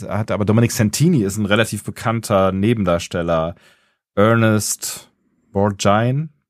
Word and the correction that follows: Borgnine: Borgine, ich weiß nicht, wie er ausgesprochen wird. Wenn du den googelst, dann den, den kennst du, glaube ich. Ernest Borgnine. Borgnine. Ja. Wird er, wird er so ja. gesprochen? Ja, ja.